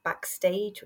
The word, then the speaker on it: BKSTG: app Backstage, which is spelled BKSTG, and it was like a music app where fans could connect with the artists more easily.